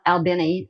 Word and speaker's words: Albany: And what had happened was that they had been in Albany, Georgia, pronounced Albany in the native tongue there.